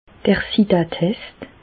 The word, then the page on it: d'r Sidatest: Bas-Rhin; Reichshoffen